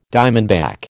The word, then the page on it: diamondback: American English